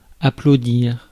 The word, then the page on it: applaudir: France; [a.plo.diʁ]